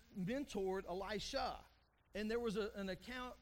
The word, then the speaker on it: Elisha: Elijah, okay, make sure you get those pronunciations right, because Elisha is different from Elijah.